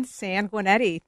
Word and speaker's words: Sanguinetti: She's done so many things for Illinois and the Midwest Governor, um, Lieutenant Governor Evelyn Sanguinetti. Did I say that right? The last name? You did, Evelyn Sanguinetti.